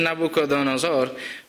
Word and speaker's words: Nabucodonosor: In Spanish it's Nabucodonosor.